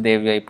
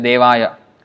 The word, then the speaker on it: Devyai: So, it's not Vrindaya Tulsi Devaya, Devo, or Devaya, it is not male, it is female.